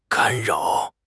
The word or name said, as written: 干扰